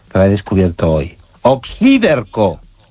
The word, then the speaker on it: Oxíderco: O-X-I-D-E-R-C-O. – Exacto, y con acento en la I. – Oxíderco, sí, sí, es esdrújula.